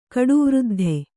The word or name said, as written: ಕಡುವೃದ್ಧ